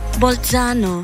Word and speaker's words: Bolzano: Bolzano.